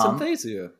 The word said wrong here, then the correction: synesthesia: You have synthesthesia. – Yeah, synesthesia. – Yeah. Oh, yeah. Sorry. There you go. – I think that's how you say it, synesthesia. – Yeah, I think you're right.